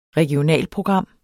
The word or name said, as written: regionalprogram